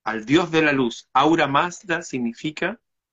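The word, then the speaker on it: Ahura Mazda: que en lenguaje farsi, el lenguaje persa, se dice así. Aura Mazda, Aura Mazda.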